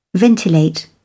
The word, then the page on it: ventilate: British English